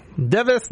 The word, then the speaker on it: DEV_IS: So there is no longer Hololive Japan, no longer Hall Alive English, no longer Hall Alive Indonesia, no longer Hall Alive Device, which is not spelled actually device, D-E-V-I-S. Device, device, it's pronounced device.